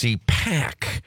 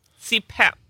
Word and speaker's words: CPAP: Okay, first of all, I tried to correct you guys, saying that you guys were saying it wrong, that it's CPAC, and then CPAP.